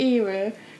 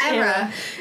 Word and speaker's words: era: You said errors quite a lot, but isn't it era? – Uh-huh. – Oh, is that just your accent, or are you just not saying it right? – Oh, it just looked like it should be said like that, but it's era.